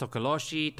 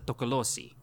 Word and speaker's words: Tokoloshe: And for those that are listening, it's pronounced Tokoloshe, Tokoloshe, or more locally as a Tokolosi.